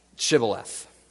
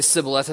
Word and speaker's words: Shibboleth: You see, the idea of this was the insiders, the Gileadites, knew the right way to say Shibboleth. But the outsiders would say Sibboleth, and therefore if you're an outsider, you deserve to die.